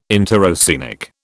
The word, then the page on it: interoceanic: British English